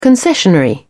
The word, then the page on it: concessionary: British English